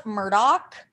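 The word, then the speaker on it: Murdaugh: Yeah, it's actually pronounced Murdoch.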